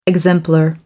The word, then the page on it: exemplar: American English